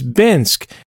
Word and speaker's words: Binsk: And I did, it was in my own way, you know, my nerdy way, I was like, oh, okay, so it's Binsk. Like, it rhymes with the city Minsk.